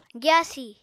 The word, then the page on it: gyaasi: apatani